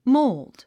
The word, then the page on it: mold: móuld; モーゥド